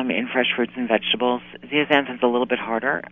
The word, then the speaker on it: zeaxanthin: Is it Lutzen and zeaxanthin? Are you familiar with those things? – You're doing that on purpose. It's lutein and zeaxanthin.